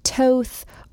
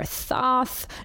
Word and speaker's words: Thoth: And then there's Thoth, sometimes pronounced Toth or Toth or Thoth. No one really knows since ancient Egyptian isn't spoken anymore, but he is a god of writing and wisdom and occult knowledge.